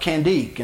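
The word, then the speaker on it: Candace: of Candique. And that C is a K in the way they pronounce it.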